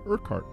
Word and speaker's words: Urquhart: You spell that U-R-Q-U-H-A-R-T, although I pronounce it Urquhart. Well, I'm supposed to pronounce it Urquhart.